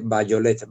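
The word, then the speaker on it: Vajolet: Eh, las agujas de Bayolet, Bajolet se escribe.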